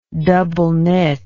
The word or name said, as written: doubleknit